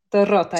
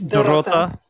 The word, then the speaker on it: Dorota: Dorota, yes. It's Dorota. Really harsh, the pronunciation in my language. So it's Dorota.